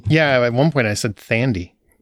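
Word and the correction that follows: Thandie: and I felt horrible. And then somebody's like, you know, it's Tandy, right? I'm like, is it?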